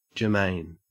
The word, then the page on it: germane: Australian English